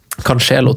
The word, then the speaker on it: Cancelo: Cancelo, tror du det uttales. – Ja, riktig. Sorry.